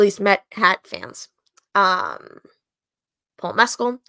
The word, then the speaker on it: Mescal: And that is how you pronounce his name, not Mescal, Paul Mescal.